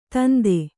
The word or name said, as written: ಧಂಧೆ